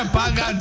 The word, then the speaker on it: Pakkan: I need a pagan chewagan. One who will pick the money, bro. – And it's spelled P-A-K-K-A-N, but again, this is where the K sounds kind of like a G.